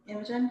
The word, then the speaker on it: Imogen: Imogen, because that's how you're supposed to pronounce it.